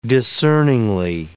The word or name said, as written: discerningly